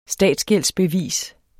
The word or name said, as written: statsgældsbevis